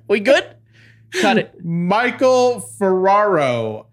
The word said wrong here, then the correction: Ferrario: Uh, it's Ferrario, you donkey.